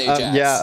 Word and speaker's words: Ajax: But It's pronounced Ajax. Ajax. – Of course. That's how the ancient Greek people also pronounced it. Yeah. They said Ajax, you know, with a thick American accent, you know?